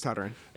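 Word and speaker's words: Toderian: Yeah, it's pronounced Totteren. – Okay. – I know, it's spelled, for our listeners at home... – It's Tadarian.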